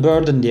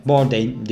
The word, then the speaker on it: Bourdain: Çünkü ben bunu Burden diye okuyordum ilk önce. Sonra baktım biyografiden önce. Bourdain diye okunuyormuş.